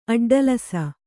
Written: ಅಡ್ಡಲಸ

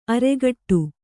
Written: ಅರಘಟ್ಟ

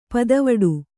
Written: ಪುದಿವೆಟು

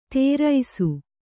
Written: ತೇರೈಸು

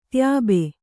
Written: ತ್ಯಾಬೆ

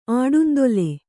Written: ಆಟಂದಲೆ

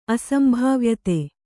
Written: ಅಸಂಭಾವ್ಯತೆ

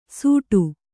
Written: ಸೂಡು

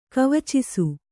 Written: ಕವಚಿಸು

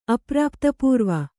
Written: ಅಪ್ರಾಪ್ತಪೂರ್ವ

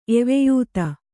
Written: ಎವೆಯೂತ

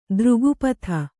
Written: ತ್ರಿಗುಪ್ತಿ

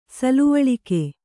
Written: ಸಲುವಳಿಕೆ

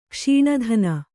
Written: ಕ್ಷೀಣಧನ

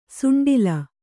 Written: ಸುಂಡಲು

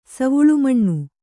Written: ಸವುಳುಮಣ್ಣು

